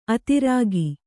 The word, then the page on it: ಅದುರುಗ: aduruga